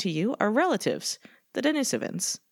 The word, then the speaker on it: Denisovans: Anyway, we're talking about the Denisovans, which I choose to pronounce that way because I looked it up and it turns out. – What, did you ask one? – No, I asked the phonetic, because it's named after Denisova Cave, which is where they were found. And that's apparently how you pronounce it. I've been saying Denisovans.